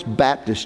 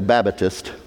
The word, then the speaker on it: Baptist: I've had folks that have said to me, now, you're a Baptist, and in y'all's Baptist church, they usually like to say it like that, or if they're from down south, they'll say Babatist. I don't know where they get the extra syllable.